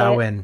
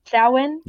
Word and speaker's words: Samhain: It was originally Samhain, however you say Samhain. – It. Samhain? – Yes, that's how you say it.